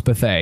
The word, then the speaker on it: Bethea: Bethea was very nice. He was like, yeah, man, it's Bethea. And I was like, okay, now I know. Yeah. Antoine Bethea. So I had always heard it pronounced and had pronounced it Antoine Bethea.